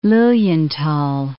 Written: Lilienthal

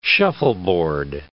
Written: shuffleboard

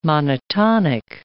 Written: monotonic

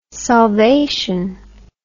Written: solvation

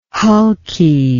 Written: hulky

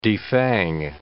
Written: defang

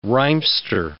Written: rhymester

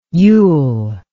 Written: youll

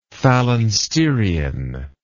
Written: phalansterian

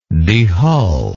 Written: dehull

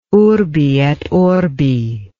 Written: urbi et orbi

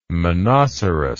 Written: monoceros

